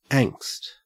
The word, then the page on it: angst: Australian English